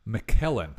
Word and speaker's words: McKellen: Is it McClellan or McKellen? Am I saying his name wrong? – I think it's McClellan. – McKellen. Ian McKellen. I've been saying his name wrong the whole time.